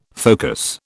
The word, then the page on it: focus: British English